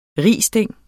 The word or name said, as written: risting